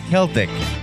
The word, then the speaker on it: Celtic: The standard way to say Celtic is a hard C that sounds like a K. So oh. It is Celtic.